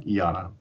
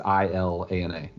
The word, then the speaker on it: Ilana: It is Ilana, not like Iana. – All right, well, yeah. She's Jewish. Because on the poster it's in all caps, so it is ILANA.